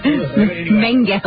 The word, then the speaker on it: Mengele: Mengele.